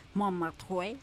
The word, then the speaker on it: Montmartre: Pero el domingo pasamos por Montmartre, perdón mi pronunciación del francés, ya sé que así no se pronuncia. – Montmartre.